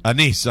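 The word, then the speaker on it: Anaïs: I think I mispronounced that. It's A-N-A-I-S. How would one pronounce that word? – Anise, I believe. – Anise, I'm terribly sorry.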